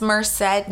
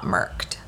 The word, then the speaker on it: Merced: It's Merced, not Merked.